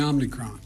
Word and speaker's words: Omicron: I might be messing up the pronunciation of this. Is it Omicron? Omicron? How do we say it? – It's called the Omicron.